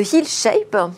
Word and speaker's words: Hill Shape: Alors aujourd'hui, vous avez jeté votre dévolu sur Sophie Brac de la Perrière, qui est PDG, cofondatrice de Hill Shape. C'est comme ça qu'on prononce Tout à fait une entreprise donc de la MedTech qui est spécialisée dans les prothèses mammaires.